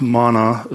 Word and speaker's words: manna: And manna, it actually means... It's actually pronounced mana as well.